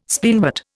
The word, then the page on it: Spleenwort: Indian